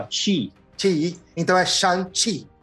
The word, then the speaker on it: Shang-Chi: Na verdade, tipo, a, a Disney deixou claro que a gente tem que aprender como se fala Shang-Chi. No filme ele fala Shang-Chi, Shang-Chi.